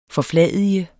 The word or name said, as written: FORFLADIGES